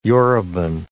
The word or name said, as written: Yoruban